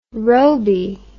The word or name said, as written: robey